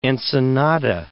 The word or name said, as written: Ensenada